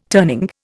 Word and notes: turning: Indian

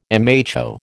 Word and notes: mho: American English

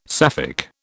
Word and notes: Sapphic: British English

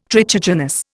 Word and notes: trichogenous: Indian

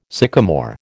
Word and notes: sycomore: American English

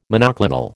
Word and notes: monoclonal: American English

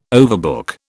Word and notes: overbook: British English

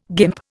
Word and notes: guimp: Indian

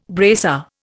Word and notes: bracer: Indian

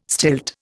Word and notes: stilt: Indian

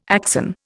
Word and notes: axon: Indian

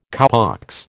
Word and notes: cowpox: American English

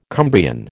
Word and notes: Cumbrian: American English

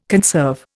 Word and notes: conserve: Indian

